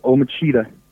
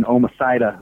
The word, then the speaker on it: Omicida: So that's that project's called Om Cheetah. And then it's, that's an Italian pronunciation. I thought it would have been Omicida or Omicida. – Right. – But Om Cheetah, Omicida.